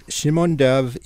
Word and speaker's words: Shimon Dov: So more about the offspring of Russian Jewish scribe Shimon Dov, is that the correct pronunciation? – That's correct. Yes, that's it. Right. Well, right. Shimon Dov,